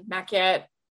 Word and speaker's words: Maquette: That are indies, Oddworld and Maquette.